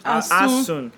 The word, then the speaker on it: Asun: You guys, you don't even know how to pronounce Asun. – Asun. – She said Asun. Look at this dusty say, girl here. – Say mm. – Say mm. – Say ah. Say ah. Soon. – Soon.